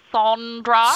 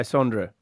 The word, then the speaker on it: Sondra: Now, let me just correct you there. It's actually Sondra. – Sorry, Sondra. Sondra, yes. Sorry. – And you pronounced my name right, the proper Italian way, Sondra.